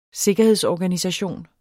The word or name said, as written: sikkerhedsorganisation